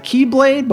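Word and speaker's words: Keyblade (χ-blade): To create what is pronounced as the Keyblade, but what is spelled as the X-Blade. Matt, if you want to mention the meaning behind we're taking an X and pronouncing it as key.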